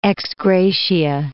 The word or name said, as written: ex gratia